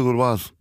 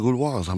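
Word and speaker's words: Gauloises: eine rote Gouloise. Oh, das ist ja gut ausgesprochen, meint er. Ich habe gesagt, wie meinen Sie das denn? Ja, rote Gouloise, haben sie richtig gut ausgesprochen.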